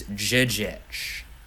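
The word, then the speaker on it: Žižić: Apparently, which I didn't realize, his name is, I guess, pronounced Jijic. Eagle did a whole Jijic breakdown of it. Said the Z's are supposed to be pronounced like J's, like Jefferson.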